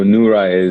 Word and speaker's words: Noura: But my daughter's name, Noura, like that you were trying to pronounce. It's so Nur actually is light, is Arabic. So Nura is, that's what we named her after, the idea of her being a light into this world.